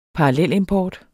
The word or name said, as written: parallelimport